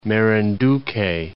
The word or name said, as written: Marinduque